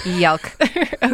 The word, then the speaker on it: Yalc: called Yalc, Y-A-L-C. – Yalc. I'm so glad you spelled that. Yalc. Okay. – Yalc, or yeah, that's how I would pronounce it.